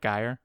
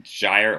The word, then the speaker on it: gyre: Am I pronouncing it right? Gyre? – Gyre or Gyre. Either one, I think, is correct.